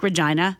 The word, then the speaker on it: Regina: Oh, that's why Regina rhymes with fun.